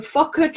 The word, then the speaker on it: Fokker: The Fokker triplane, did I pronounce that correctly? – Yep, you're good so far.